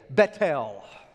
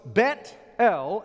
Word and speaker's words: Bethel: Now, in Texas, we pronounce it Bethel. The Israelites, they still pronounce it to this day, Bethel. The reason is, it's a compound word, two words, Bet, B-E-T-H, their H is silent. So it's Bet-El, El is the prefix for God, the word Bet is house, house of God.